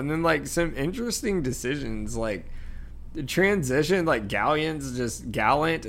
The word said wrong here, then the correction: Gallatin: Yeah, Gallatin.